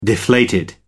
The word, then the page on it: deflated: American English